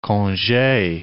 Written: conge

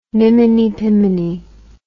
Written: miminy piminy

